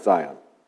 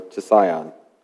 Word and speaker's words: Zion: It's Hebrew for T-S-I-Y-Y-O-N. We pronounce it Zion, or Tsion.